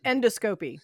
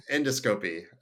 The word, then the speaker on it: endoscopy: Endoscopy. – And everything. – Yeah, it's pronounced endoscopy. – Endoscopy, which Kat will be...